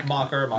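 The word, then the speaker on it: Macher: I am definitely going to fuck Billy Loomis and Stu Matcher because, am I saying that right? – Is it Mocker? – I think it was Mocker.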